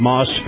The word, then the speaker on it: Maus: Moss Family Automotive, in particular, Moss Acura. Now, Moss is spelled M-A-U-S.